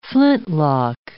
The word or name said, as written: flintlock